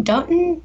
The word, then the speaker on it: Dotun: and it goes to Dun- Dotun. Dotun, I always say his name wrong.